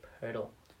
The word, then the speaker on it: Pirtle: Player named Pirtle. – Pirtle? – Yeah, it's spelled like... – Like Wordle with a P.